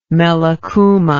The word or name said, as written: melaxuma